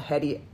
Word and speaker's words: Heti: Her last name is spelled Hetty, H-E-T-I.